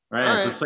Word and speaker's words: Reims: R-E-I-M-S? – Yeah. – I heard it's pronounced Reims. – Rennes? – Rennes. Right. It's like the capital of the Champagne district.